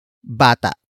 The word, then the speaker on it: bata: After that, we have bata. Bata. Bata. Bata is spelled as B-A-T-A. Note the glottal stop at the end.